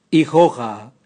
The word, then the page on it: igoga: Raga